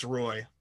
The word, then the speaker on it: Roy: And, you know, Patrick Waugh now it's history. – Hmm. With 150. You're pronouncing it wrong. It's Roy, but continue.